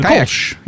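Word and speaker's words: Kölsch: Am going to go with the Kolsch. Is that how you say it? – Kayak Attack. The Kolsch. Yes.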